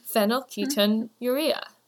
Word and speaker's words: phenylketonuria: Next is the word your, then an E, like the letter, and then a mouth saying, ah, and that's how you pronounce phenylketonuria.